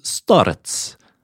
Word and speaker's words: starets: Jeg kan kanskje uttale ting noen ganger, men eh, ja, hvordan vil du si det uttales? Starets.